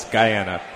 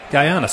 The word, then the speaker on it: Guyana: It's Guyana. – Guyana. So just like it looks.